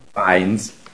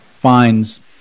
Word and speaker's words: Fiennes: Did I pronounce her name correctly? – Fiennes. – Fiennes.